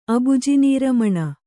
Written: ಅಬುಜಿನೀರಮಣ

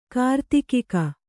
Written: ಕಾರ್ತಿಕಿಕ

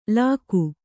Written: ಲಗೂ

